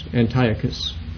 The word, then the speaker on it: Antiochus: Yes, it's like Antioch, like the city Antioch, A-N-T-I-O-C-H, and then with a U-S, Antiochus. Epiphanes is spelled E-P-I-P-H-A-N-E-S.